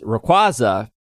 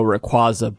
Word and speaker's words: Rayquaza: Another one is Rayquaza, because Rayquaza's actual pronunciation is, do you know what it is? – No, I don't. I thought it was Rayquaza. – Nope, it's Rayquaza.